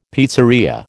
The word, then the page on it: pizzeria: American English